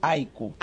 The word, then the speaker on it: haiku: Haiku, incluso ahora, claro, porque nosotros la H no la pronunciamos. No. Nosotros tendríamos que decir haiku.